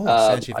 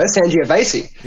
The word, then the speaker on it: Sangiovese: It was a Pensilver Sangiovese. – Oh, Sangiovese. – I think that's how you say it. – You did well. – There we go. Sangiovese.